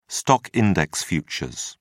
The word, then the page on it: stock-index futures: British English